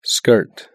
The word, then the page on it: skirt: British English